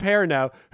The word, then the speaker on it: Perrineau: How do you say Harold's last name? Perrineau? Is that how you say it? – Yeah. – Harold Perrineau, who plays Walt's father, Michael, on Lost.